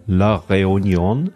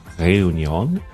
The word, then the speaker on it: La Réunion: Jens Giese ähm, aus, klingt jetzt noch gar nicht so ähm, exotisch, von der Insel La Reunion, habe ich es richtig gesagt? La Réunion. Man muss ja immer, also ich darf das bayerische R dabei nicht so rollen. Réunion.